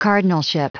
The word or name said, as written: cardinalship